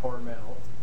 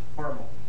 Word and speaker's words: Hormel: And he insists that most people mispronounce the name of the company because he says that his pronunciation is correct and it's not Hormel, it's Hormel.